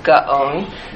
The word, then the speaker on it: Gaon: And we say Gaon instead of Gaon, but it's really two syllables, Gaon. But we just slur it together, Gaon, the Vilna Gaon.